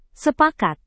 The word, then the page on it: sepakat: Indonesian